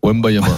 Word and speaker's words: Wembanyama: On prononce Wembanyama.